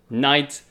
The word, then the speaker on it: knight: It begins with K, but the pronunciation is knight. So it's K-N-I-G-H-T. And you see that K and that G and that H, it has like basically no meaning.